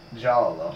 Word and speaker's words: giallo: Giallo! – Giallo. – Giallo. – Giallo. – Giallo. Do we have a translation? – That's my understanding of how it's pronounced from our Italian French.